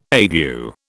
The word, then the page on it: ague: British English